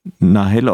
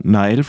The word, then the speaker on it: Nahel: Wir wollten nochmal sprechen über den Tod von Nahel oder Nahel, französisch ausgesprochen, diesem Jugendlichen, der von einem Polizisten erschossen wurde.